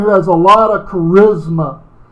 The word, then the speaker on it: charisma: That word gift there in the Greek is how we would pronounce it, charisma.